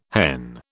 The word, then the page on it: hen: British English